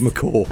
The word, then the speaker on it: macaw: And Rio is McGraw. – McCaw? Well, you could say it like that, Anna, if you want, but it's called macaw.